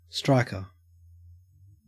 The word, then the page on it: striker: Australian English